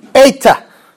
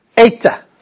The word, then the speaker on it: eta: Eta. Eta. Now, it looks like an H, but it ain't no H. It is the long A sound.